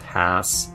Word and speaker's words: Haas: It's actually named after a guy named Rudolf Haas. Rhymes with something like a donkey, whatever.